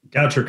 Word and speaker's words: Goucher: Goucher College. Did I pronounce that correctly, Dante? – Goucher College. – Goucher. I went French on you.